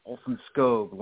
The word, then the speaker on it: Olson-Skog: And just to be fair to the last name, it's Olson-Skog, long O.